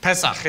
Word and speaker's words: Pesach: I mentioned the, the word Pesach. Correct, correct my pronunciation. Pesach. yeah. Okay, thank you.